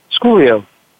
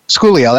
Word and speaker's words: Schoolio: Do we pronounce it Schoolio or Schoolio? – Schoolio. – Schoolio. That's what I thought. It sounds cooler that way.